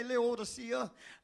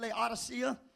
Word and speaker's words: Laodicea: He says, one of the churches that I want you to talk to is Laodicea. Some say Laodicea, but the O is silent, Laodicea.